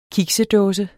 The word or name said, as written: kiks